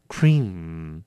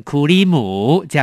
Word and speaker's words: cream: 第九个字 cream， cream 名词奶油，这个字呢也要念好，很多人都喜欢把它念成库里姆，这样有点可怕的要念成 cream， cream。